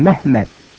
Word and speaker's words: Mehmet: You know, in the land of the Turks, in Turkey, they don't call Muhammad Muhammad. They call him Mehmet. That's how they pronounce it, Mehmet.